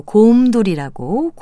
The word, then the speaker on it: 곰돌이: 그리고 곰돌이라고 곰을 길게 발음합니다.